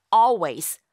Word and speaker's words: always: 실제 발음할 적에는요. all 하는 그 all, 리을 발음을 아예 하지 않아요. 그래서 always 라고 발음하게 됩니다.